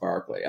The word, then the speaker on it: Berkeley: I used to call him Reverend Berkeley until someone corrected me. It's actually pronounced Barkley.